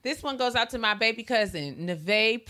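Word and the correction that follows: Nevaeh: Prude. I hope I'm saying that right. – Is it Nevea? It's Nevea. – I'm sorry. Nevea. You're right.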